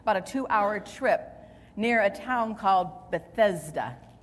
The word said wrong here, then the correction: Bethsaida: Uh, Bethsaida, I pronounced it wrong.